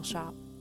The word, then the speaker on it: scop: I act as a shop, an old English word spelled S C O P, but pronounced like a retail shop.